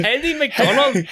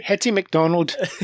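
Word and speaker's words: Hettie MacDonald: That's Irish. – That's Irish. Hetty. Hetty McDonald. – Hetty McDonald.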